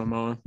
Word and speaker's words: Momoa: Momoa? Is that his name, Jason? – Yeah. Jason Momoa.